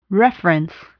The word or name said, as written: reference